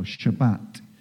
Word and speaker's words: Shabbat: There's only one day that has a name, and that's the Sabbath, the seventh day, or Shabbat, as it's pronounced.